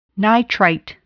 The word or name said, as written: nitrite